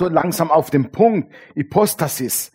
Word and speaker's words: Hypostasis: Hypostasis. Habe ich es richtig ausgesprochen, Vicky? Sag mir nochmal. Hypostasis? Hypostasis. Hypostasis. So spricht man es aus.